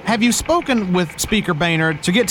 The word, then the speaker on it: Boehner: Ollie, again, it's Boehner. It's pronounced Boehner. – But it's spelled Boehner. – I know, Ollie, but it's not... Never mind.